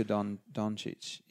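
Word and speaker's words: Dončić: Luka Doncic.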